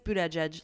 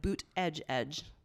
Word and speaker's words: Buttigieg: Well, I just looked up how to say it, and I believe it's Buttigieg, like boot edge edge. – Buttigieg. – Buttigieg.